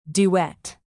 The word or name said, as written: duet